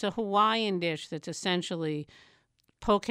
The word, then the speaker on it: poke: But never fear, a food writer for 7 days, and she's here to give us a poke primer. Welcome back to the VPR Cafe. – Hi, Rick. – Okay, am I even pronouncing it correctly? – Yes, poke.